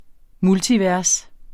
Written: multivers

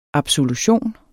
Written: absolution

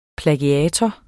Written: plagiator

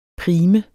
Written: prim

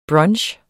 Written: brunch

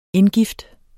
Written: indgift

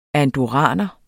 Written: andorraner

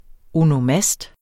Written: onomast

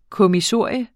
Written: kommissorium

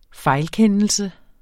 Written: fejlkendelse

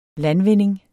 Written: LANDVINDINGS

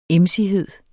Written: emsighed

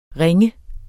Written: ringe

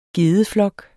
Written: gedeflok

